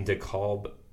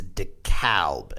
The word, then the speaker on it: DeKalb: Illinois, excuse me, in DeKalb, if I'm saying that right. – You are. Illinois. It's actually, so I recorded our interview last night with our guest, and it's DeKalb, and he was quite clear about that and insists on the, he called it the Chicago A.